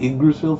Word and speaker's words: Yggdrasil: You got Yggdrasil from a Y and two G's next to each other? – That's how you say it.